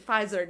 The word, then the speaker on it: Pfizer: Pfizer.